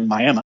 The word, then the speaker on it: Miami: Then you go to the town of Miami there, and it's spelled Miami, right? – But it's actually Miami.